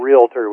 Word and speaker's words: realtor: I can pronounce real estate and realtor, which is two syllables, by the way.